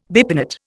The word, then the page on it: bipinnate: Indian